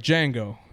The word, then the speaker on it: Django: He's like, Django. And then he says, can you spell it? And he says, D-J-A-N-G-O. Yeah, D-J-A-N-G-O. And then he tells the guy, is like, the D is silent.